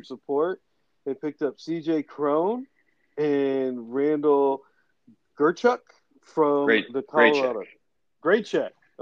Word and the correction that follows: Grichuk: That's pronounced Grichuk. Grichuk is Grichuk.